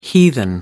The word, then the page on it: heathen: American English